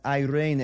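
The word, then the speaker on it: eirene (εἰρήνη): Your third and final Greek word this morning is the Greek word for peace, which is pronounced eirene. Let me hear it. Eirene.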